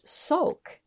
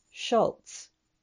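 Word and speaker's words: Šolc: Their name is spelled S-O-L-C, and you would think it would be pronounced Solk, but it is a Czech name, and so you say Schultz.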